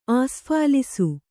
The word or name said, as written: ಆಸ್ಫಾಲಿಸು